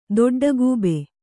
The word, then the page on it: ದೊಡ್ಡಗೂಬೆ: dodḍa gūbe